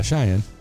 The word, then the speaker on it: Cheyenne: Cheyenne? Am I even saying that right? – Who? – Yeah, Cheyenne.